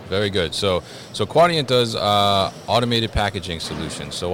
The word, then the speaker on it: Quadient: They just rebranded from Neopost, and now they are Quadient, and we were discussing how to pronounce it correctly. Yeah. So it's Quadient, right? – Yes.